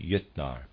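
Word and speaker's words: Jötnar: Readers aside, Jotnar is spelled J O umlaut T N A R, and I'm pronouncing that Jotnar probably is more accurate.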